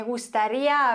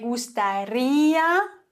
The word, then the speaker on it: gustaría: Me gustaría hablar contigo. Wieso? Naja, zunächst mal müssen wir immer die Silbe betonen, auf der ein Akzent liegt. Bei gustaría ist es deswegen klar auf dem I. Me gustaría. Bei hablar haben wir dann die Regel,